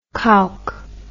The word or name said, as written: cowk